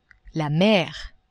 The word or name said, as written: mer